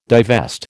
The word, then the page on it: divest: American English